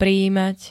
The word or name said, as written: prijímať